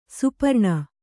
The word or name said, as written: ಸೂಪರಣ